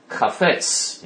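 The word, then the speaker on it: Chaffetz: Jason Chavitz, Chafetz in Hebrew. Chafetz is how you pronounce his name in Hebrew.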